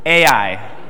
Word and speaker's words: Ai: Ai. – Just say it like it looks. A-I. A-I. Not I-E. Okay, A-I.